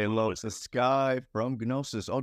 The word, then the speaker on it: Gnosiiis: And also another thing, it's pronounced Gnosiiis. The G is silent.